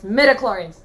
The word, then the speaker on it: midichlorians: It's midichlorians.